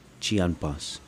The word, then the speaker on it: chinampas: Chinampas. rhymes with Salonpas.